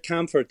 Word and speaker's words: Kaempfert: You also have things in like Bert Kaempfert. Is that how it's pronounced? – Oh, Bert Kaempfert. Yeah, he was a German orchestra, mainly brass, some brass and some strings.